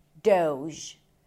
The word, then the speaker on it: Doge: This is an internet meme from 2013, and the way you pronounce it is Doge. Doge. It's a D sound, O as in go, and then the zh sound, as in vision. Zh. Doge.